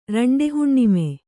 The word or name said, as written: ರಂಡೆಹುಣ್ಣಿಮೆ